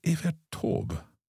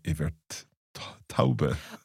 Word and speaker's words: Evert Taube: So is Evert Taube? – Mm. – I have always in my head pronounced that Evert Taube. – Oh, no. Evert Taube.